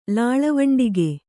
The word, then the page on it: ಲಾಳವುಂಡಿಗೆ: lāḷa vuṇḍige